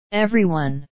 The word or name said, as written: everyone